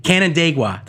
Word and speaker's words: Canandaigua: See if I can say, it's Canandaigua or something like that. Canandaigua, that's how you pronounce it.